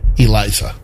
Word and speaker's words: Eliza: The way they have it written, it says pronounced IH-L-AY-Z-A. Well, A-H. Eliza.